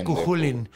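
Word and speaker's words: Cú Chulainn: Pero sí, se pronuncia Cuchulín.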